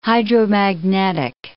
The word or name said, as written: hydromagnetic